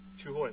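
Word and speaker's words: Chiêu Hồi: and the Army had one of those Chu Hoi scouts, whatever mm-hmm. how you pronounce it. – Chu Hoi, that's right.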